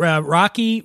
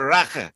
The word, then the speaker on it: raki: I pronounced it wrong when I got there. I said Rocky. And they have it's like a short A sound, which is strange because it's spelled R-A-K-I. They call it Raka, like that.